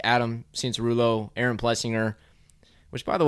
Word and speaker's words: Plessinger: And he pretty much was like, well, my whole life has been Plessinger, pronounced like the hard G sound, but he pretty much was like, people call me whatever.